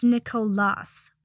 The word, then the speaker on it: Nicolas: Potter, you dullard, it's Nicolas, with a silent S on the end. It's French, obviously the equivalent of Nicholas.